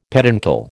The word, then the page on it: peduncle: American English